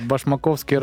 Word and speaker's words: Башмаковский: Я погуглила ударение, это Башмаковский район. – А где он находится? – Где-то. – Ну, типа, Башмаковский район города...